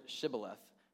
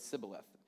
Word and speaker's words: Shibboleth: So they told him to pronounce the word Shibboleth, but Jephthah could not, and instead said Sibboleth.